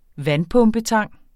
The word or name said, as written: vandpumpetang